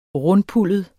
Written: rundpuldet

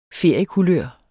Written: feriekulør

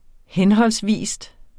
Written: henholdsvis

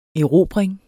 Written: erobring

